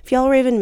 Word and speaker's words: Fjällräven: And I feel like I have to acknowledge how difficult the name Fjällräven is. It's spelled F-J-A-L-L, Raven, and it means Arctic fox in Swedish. But for those of us who don't speak Swedish, it's a little tough to pronounce.